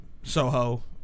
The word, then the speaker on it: Sauhau: One works at the public works in Soho, okay. – Indiana. – Oh, okay. – Which is spelled S-A-U-H-A-U.